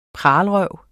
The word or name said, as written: pralerøv